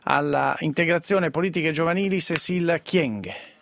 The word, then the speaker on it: Kyenge: Intanto ci tolga il dubbio, la pronuncia è corretta, Kyenge, perché l'abbiamo sentita pronunciare in tanti modi. – Sì, è corretto, Kyenge.